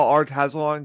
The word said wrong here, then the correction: Talsorian: got its name. – Oh, yes. – Or Talsorian. I'm sorry. I always say it wrong.